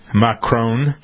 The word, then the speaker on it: Macron: That's the way to pronounce it. Macron.